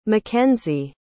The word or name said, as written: makenzie